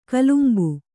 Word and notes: ಕಲಂಬು: kalambu

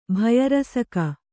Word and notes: ಭಯರಸಕ: kannada

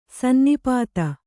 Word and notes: ಸನ್ನಿಪಾತ: sanni pāta

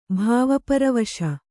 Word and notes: ಭಾವಪರವಶ: bhāva paravaśa

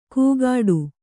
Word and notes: ಕೂಗಾಡು: kugāḍu

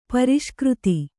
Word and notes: ಪುರಸ್ಕರ್ತ: kannada

